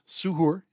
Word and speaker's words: suhur: This sign is pronounced suhur in Sumerian, and it refers to not just fish, but split, salted, and dried fish.